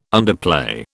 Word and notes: underplay: British English

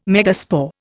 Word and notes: megaspore: Indian

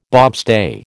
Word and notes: bobstay: American English

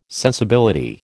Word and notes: sensibility: American English

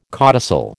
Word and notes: codicil: American English

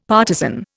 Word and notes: partizan: Indian

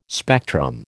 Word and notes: spectrum: American English

